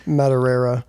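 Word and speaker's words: Madureira: Yeah, so this was Joe Matarera, which yeah, it's spelled in a manner in which you would never think that that's how it's pronounced, but yeah, it's Matarera, and he is...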